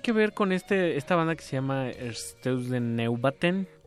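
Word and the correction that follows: Einstürzende Neubauten: Einsturzende Neubauten.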